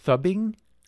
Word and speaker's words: phubbing: Fubbing? – Fubbing. – Spell that for us. – P-H-U-B-B-I-N-G.